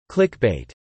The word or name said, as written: clickbait